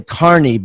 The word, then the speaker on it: Kearney: Now, guys, here's how it's spelled. It's actually Kearney, but you know, you would think like I had a friend in college, last name Kearney, was Carney, but I don't want you to remember that because that's not how he does it.